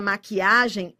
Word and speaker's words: maquiagem: Na palavra maquiagem, eu tenho o que com U, né? Porque o U não é pronunciado, ele não representa um fonema, né?